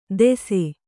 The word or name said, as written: ದೆಸೆ